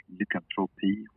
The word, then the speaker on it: Lykantropi: Um, it's probably like Lykantropi, which is uh, like lycanthropy, but in, pronounced in Swedish or spelled in Swedish, mm-hmm.